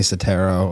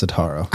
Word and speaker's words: Setaro: Yeah, people say Setaro a lot. I got that growing up. It's Setaro.